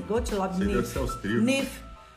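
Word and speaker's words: Gottlob Neefe: Gottlob Niff.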